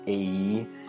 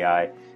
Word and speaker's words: Ai: And for, those Hebrew scholars out there, Ai is typically pronounced A-E in the Hebrew language, but I'm probably going to say Ai because that's, what I've grown up with.